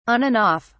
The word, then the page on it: on and off: British English